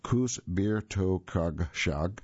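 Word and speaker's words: közbirtokosság: And the Kocsberkasag, and actually, let me give you a better pronunciation. It's Kocsberkasag, Kus Bir To Kug Shug.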